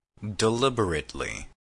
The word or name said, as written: deliberately